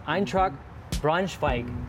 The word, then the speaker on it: Eintracht Braunschweig: And I want to start with your time in Germany with Hanover versus, and I'm going to do my pronunciation here, versus Eintracht Braunschweig. Mm-hmm. Did I get it close at least? – Yes, but you won't hear me say that word.